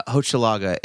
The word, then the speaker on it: Hochelaga: Uh, Hochelaga, H O C H E L A G A uh, and I'm not spelling Dopethrone.